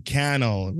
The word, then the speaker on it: Cannell: You were talking to Sean Cannell, rhymes with YouTube channel, because he always says that.